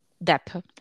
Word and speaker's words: Depp: Lily Rose Deep. Depp, chyba wymawia się Depp.